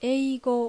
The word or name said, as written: Eigo